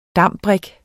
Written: dambrik

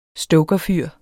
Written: stokerfyr

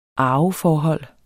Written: arveforhold

